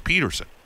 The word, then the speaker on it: Pettersson: First of all, so I'm watching this thing on Elias, and I thought for sure it was Pettersson, and he actually says it's Peterson. So has anybody changed the pronunciation they're going with? – Well, he's kind of trying to make it a little bit of a North American Peterson, but, in actuality, it's Pettersson, but